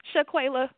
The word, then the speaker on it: Shaquayla: I actually called because you've been pronouncing my name wrong for so long that I had to call you and say, look, my name is Quayla, Shaquayla, okay? That's how Quayla.